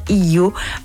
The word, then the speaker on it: Hiyo: Elles s'associent pour donner naissance à Hiyo, Hiyo, H-I-Y-O. Alors, comment vous le prononcez? On ne sait pas. Toujours est-il que phonétiquement, ça se prononce Hiyo.